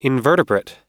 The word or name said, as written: invertebrate